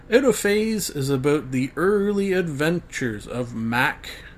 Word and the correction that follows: Mach: M A C H, as in, uh, no, how would you pronounce that again? I've just been reading it in my brain, Mac uh, Mach, yeah, as in like Mach Speed, M A C H, and Bane.